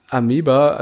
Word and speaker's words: Amoeba: Amoeba, also das ist so, wo man wahrscheinlich jede Platte der Welt kriegen würde.